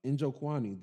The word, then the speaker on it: Nzechukwu: Nzechukwu? – Nzechukwu.